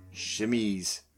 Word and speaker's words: chemise: And that is spelled C-H-E for echo, M-I-S-E for echo. Chemise.